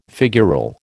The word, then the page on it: figural: American English